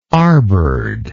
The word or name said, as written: arbored